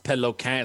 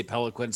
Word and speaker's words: Peloquin: Uh, so my name is actually pronounced Peloquin. It's French Canadian, but I just say Pelican, so I don't sound like a total douche.